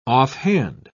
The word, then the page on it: off-hand: British English